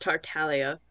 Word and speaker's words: Tartaglia: It's actually pronounced Tartaglia. – Tartaglia. – Okay, yeah. the G is silent. Gotcha. The G is actually silent.